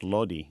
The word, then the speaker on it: Lodi: Even though Lodi is actually named for an Italian wine region, but it's pronounced Lodi Even a glass of Sonoma.